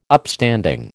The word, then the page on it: Upstanding: American English